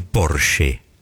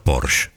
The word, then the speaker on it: Porsche: La marca es alemana y se dice Porsche. No Porsche. – Porsche. – Esa E final en alemán se pronuncia.